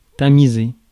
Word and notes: tamiser: France; /ta.mi.ze/

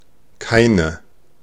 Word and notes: nessuno: /nes.ˈsu.no/

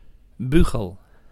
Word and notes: clairon: France; [klɛ.ʁɔ̃]

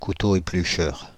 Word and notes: couteau éplucheur: Île-de-France; /ku.to e.ply.ʃœʁ/

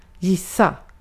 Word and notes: gissa: /ˈjɪsˌa/

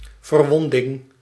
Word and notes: verwonding: /vǝɾ.ʋɔn.dɪŋ/

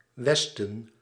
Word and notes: westen: /ˈʋɛs.tə(n)/

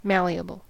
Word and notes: malleable: American English; /ˈmæliəbəl/; English

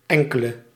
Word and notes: some: /sʌm/; [sɐm]; /sam/; [säm]

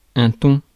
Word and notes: thon: France; [tɔ̃]

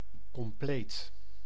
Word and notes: complete: American English; [kəmˈpl-iːt]

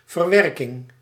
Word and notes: verwerking: /vǝɾ.ʋǝɾ.kɪŋ/

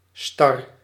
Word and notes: raide: France; /ʁɛd/

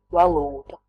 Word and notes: currency: American English; IPA: /ˈkɝ.ən.si/

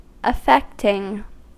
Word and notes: affecting: [ə.ˈfɛk.tɪŋ]

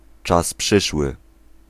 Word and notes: futur: France (Paris); [lœ fy.tyʁ]

